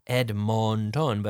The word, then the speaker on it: Edmonton: The first one was Edmonton, E. E as in España, en España, but E-D-M-O-N-T-O-N, Edmonton, but Edmonton, we say. And the second one was Regina, R-E-G-I-N-A, se escribe Regina.